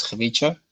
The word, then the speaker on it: Khvicha: So say KH in the beginning, right? So it's Kh. Okay. Like a ch, typically, and we see a ch, which is a ch, so it's Khvicha. His name is Khvicha, which may sound quite difficult at first, but it's okay, easy so to get along with.